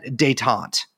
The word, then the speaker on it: détente: That basically, the idea was to essentially end the Cold War and to cement détente. Am I pronouncing that correctly? – Yeah.